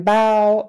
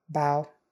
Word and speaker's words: bough: And then bough, meaning like a branch on a tree, a bough, bough. Okay, so those are six ways you can pronounce the O-U-G-H sound in American English.